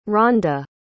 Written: Randa

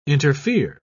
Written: interfere

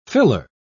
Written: filler

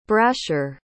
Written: brasher

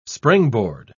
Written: springboard

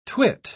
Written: twit

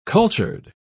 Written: cultured

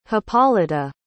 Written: hippolyta

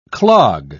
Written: clog

